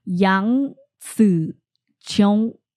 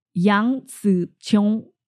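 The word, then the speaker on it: Yang Ziqiong (楊紫瓊): Her full Chinese, the full Chinese pronunciation of her name is Yang Ziqiong. Yang Ziqiong.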